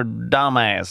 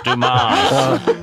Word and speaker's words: Dumas: The Count of Monte Cristo by Alexander Dumbass. – Dumbass. Hur många filmen igen? Bara, no. – It's Dumas.